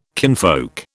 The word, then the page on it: kinfolk: British English